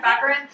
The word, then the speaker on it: Baccarin: Like Marina Baccarin, or is that how you say it? – Baccarin.